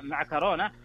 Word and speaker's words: معكرونة: خلنا ناخذها أول شيء النطق الأصح يعني وش هو أو الأكثر شيوعا هو بالكاف طبعا معكرونة هذا النطق الصحيح ويمكن الدارج في أغلب الدول. أما مكرونة فهو نطق دارج منتشر في بعض اللهجات ويستخدم كثيرا في الحديث اليومي لكنه يعني أقل يقولون أقل فصاحة من حيث التعريب المتداول. وترى